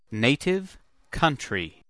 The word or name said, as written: native country